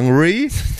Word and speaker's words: Hungry: Genau, kommen wir zu unserem dritten Thema, auch wieder eine junge Serie, die heißt Hungry. Oder vielleicht sollte ich es ein bisschen englischer aussprechen. – Englischer, ja. – Hungry.